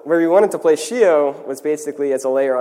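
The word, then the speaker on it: Scio: So Shio, I've heard of many different pronunciations. The way we like to call it is Shio, with the full, you know, hand gesture.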